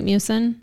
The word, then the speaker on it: mucin: Am I saying that right, mucin? – Mm-hmm.